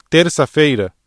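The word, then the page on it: terça-feira: Brazilian Portuguese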